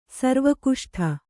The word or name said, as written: ಸರ್ವಕುಷ್ಠ